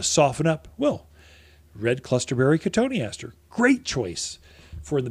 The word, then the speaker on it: cotoneaster: Another one that's kind of like that is cotoneaster, or cotton easter is how people spell it, but cotoneaster is how you pronounce it.